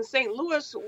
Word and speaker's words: St. Louis: Well, I love talking about St. Louis, but in St. Louis, we do not have the French pronunciation. We say yes on the end of Louis, okay.